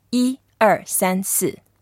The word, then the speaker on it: 一二三四: 一二三四. – 一二三四.